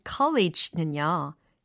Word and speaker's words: college: 그래서 앞에 있는 용기에 해당하는 단어는 c, c, courage, courage 이렇게 하셔야 되고요. 두 번째 단어인 college는요. 카, 카, college 이렇게 하셔야 돼요.